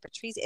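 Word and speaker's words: Patrizia's: Is that how you say it? Patricia. It's like with a Z in Massapequa. And it's, oh my God, it's so good.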